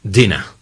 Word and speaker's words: dinner: Diner es un lugar donde podemos ir a comer en Estados Unidos. Y se pronuncia diner. Pero cuando estamos pidiendo la cena, decimos dinner. – Dinner. – Son dos palabras que se escriben igual, pero se pronuncian diferente dependiendo de si estamos pidiendo la cena o es un establecimiento al que vamos a comer.